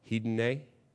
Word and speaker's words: hedone: It's the word in Greek, and you'll know exactly what word we get from this when I pronounce it. Hedone. Hedonism. Okay.